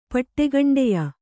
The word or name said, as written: ಪಟ್ಟೆಗಂಡೆಯ